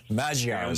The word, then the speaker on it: Magyar: What's on my mind is the new prime minister of Hungary, Peter Magyar. – Yeah, Magyar is how it's pronounced.